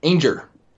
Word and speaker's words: Anger: Anger. It's like danger without the D.